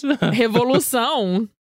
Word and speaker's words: Revolução: Revolução.